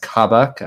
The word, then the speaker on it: Kabak: I think his name is pronounced Kabak. I could be mispronouncing that. The youngster they just bought from Germany. – Yeah, that's right.